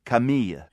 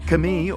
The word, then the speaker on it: Camille: If you don't know her yet, this is French singer Camille. – La sauce, si la sauce, si la sauce, dans la sauce. – Camille, or Camille, as we pronounce it in English, is super well known in France.